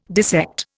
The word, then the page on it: dissect: Indian